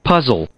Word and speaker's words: puzzle: Puzzle. Puzzle. Puzzle. Ya, mejor. Puzzle. So, el, sonido es una reversed E. Recuerden que es como uh, puzzled.